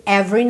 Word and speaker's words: every: O E Do meio ali de every não é pronunciada, tá? Every, pensa VR, every, every day.